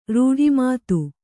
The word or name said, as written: ರೂಢಿಮಾತು